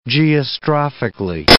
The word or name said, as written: geostrophically